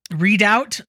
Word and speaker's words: Redoubt: No, wait, this is about something called the Great American Redout. Redoubt, I think. – Redoubt. – Yes. – Mm-hmm. It's spelled red and then OUBT.